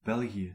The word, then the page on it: Belgium: IPA : [ˈbɛl.dʒəm]